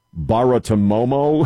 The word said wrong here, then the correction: Bartiromo: Romo, Baratomomo. – Bartiromo.